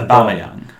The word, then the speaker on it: Aubameyang: How do you pronounce that lad's name who they got for Aubameyang? – And like him, like...